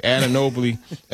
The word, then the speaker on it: Anunoby: I can never pronounce his name. Excuse me. Anunoby. I was going to put that L in there for some reason.